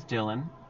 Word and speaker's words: Dylan: Because I say my name is Dylan, like Bob Dylan, the singer, and I do a little thing where I pretend I'm playing the guitar, like a little hand gesture.